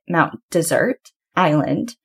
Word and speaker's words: Mount Desert Island: And if you ever hear locals talking about it, they actually pronounce it Mount Desert Island.